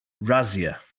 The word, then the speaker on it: Razzia: So apparently, how I'm supposed to pronounce this in German is I say Razzia.